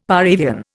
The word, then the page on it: par avion: Indian